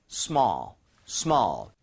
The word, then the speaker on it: small: However, 발음할 때 small 아니라 ALL. 혹은 AL 이런 스펠링은 입모양 벌리자고 했었죠. 그래서 small에 더 가깝습니다. 보여드릴게요. Small, small.